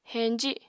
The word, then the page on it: heñji: apatani